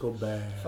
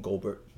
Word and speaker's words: Gobert: You have to say it like the French, Gobert. – Fuck that, I'm going to call him Gobert.